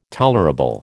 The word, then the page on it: tolerable: American English